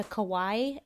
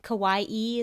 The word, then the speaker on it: Kauai: Yeah, I visited there once and I went to Kauai, but isn't it Kauai-e is how you're supposed to say it? – Correct. – The island Kauai-e. Of Kauai? – Correct. – Kauai-e.